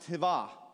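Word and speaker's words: Hava (חוה): In Hebrew, Eve is pronounced Hava.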